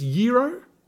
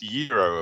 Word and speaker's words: gyro: It's spelt gyro. Looks like a kebab to me. It's pronounced Euro, apparently. – And I have recently located a takeaway place not far from my work, and they spell it Y-I-R-O. So I'm thinking it's Euro.